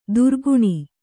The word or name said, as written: ದುರ್ಗುಣಿ